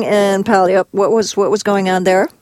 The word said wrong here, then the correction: Puyallup: Puyallup? – Puyallup, yeah.